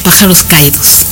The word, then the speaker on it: Pájaros Caídos: Ha liderado proyectos culturales de impacto internacional, como el grupo musical Los Pájaros Escaídos y su sello editorial ¿Escaídos o Escaídos? ¿Cómo lo pronuncias? – Pájaros Caídos. – Ah, la S no.